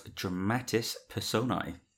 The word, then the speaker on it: Dramatis Personae: We are talking about Dramatis Personae. I think I've said that right. – I think it sounded okay. – I'm assuming I pronounced the S on Dramatis.